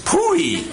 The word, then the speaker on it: POEE: That's abbreviated P-O-E-E and pronounced Pooey.